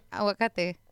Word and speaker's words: aguacate: What did you just say? – Aguacate. – Is that how you say it? – Yeah, aguacate. – What? Oh, okay. – That's how we say it.